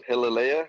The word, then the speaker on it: Hialeah: Yeah, it was my first time in Miami, and I got to go visit this town of Hialeah, I think it's called. And correct me if I'm wrong, Rick, is that how you say it? – That's how you say it.